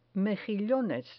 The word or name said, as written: mejillones